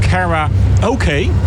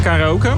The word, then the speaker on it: Kara Oké: Daar draait op dit moment Kara Oké. Ja, dat spreek je volgens mij uit als karaoke op z'n Engels. Maar het is Kara en dan Oké op z'n Engels. Oké.